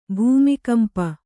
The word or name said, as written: ಭೂಮಿಕಂಪ